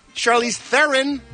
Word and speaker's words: Charlize Theron: Charlize Theron. That's how it's pronounced.